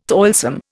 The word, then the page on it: toilsome: Indian